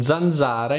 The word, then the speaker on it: zanzara: In zanzara invece il suono della Z è più dolce, proprio come il suono che fa una zanzara. Zzz.